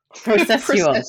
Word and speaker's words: processual: How do I say it? – Processual.